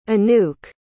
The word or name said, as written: anouk